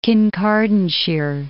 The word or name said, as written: Kincardineshire